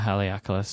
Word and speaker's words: Haleakala: Um, and I also bought some uh, Robert Oster Yellow Sunset ink, which is for my Kanilea Haleakala silhouette.